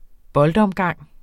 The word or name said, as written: boldomgang